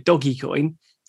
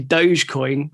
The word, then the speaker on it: Dogecoin: I think you called it Dogecoin, but it's actually Dogecoin, we've since found out.